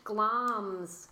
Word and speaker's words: Glamis: The Beast of Glamis Castle, which I believe is pronounced Glams. – Glams. – Glams. I don't know whether to go for just my own phonetic pronunciation or whether I have big hopes for your Scottish accent on this episode. – It's spelled G-L-A-M-I-S, the Beast of Glams Castle.